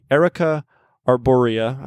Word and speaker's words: Erica arborea: And we'll backtrack a little bit and say, briarwood comes from the root of the Erica arborea. I always pronounce that wrong, but I think that was right. So you don't always pronounce it wrong.